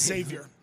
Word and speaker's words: Xavier: Well, it's Xavier. Xavier. I used to say Xavier. – Well, because Xavier Talton and Xavier Johnson both like the X pronounced.